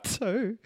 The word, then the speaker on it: Ptoe: Like P-T-O-E. How the fuck do I pronounce that? – Ptoe.